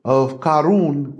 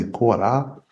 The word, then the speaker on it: Korah: So this Dathan and Abiram, you know, good friends of Karun, which is how they say it in Arabic, but we say Korah.